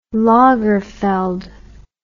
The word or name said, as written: Lagerfeld